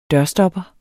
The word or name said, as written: dørstopper